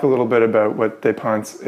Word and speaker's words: dépenses: There was one article in particular that really struck me, which was called In Defense of Our Dépenses. I don't know if I'm pronouncing that word right. – Dépenses. It's a French word.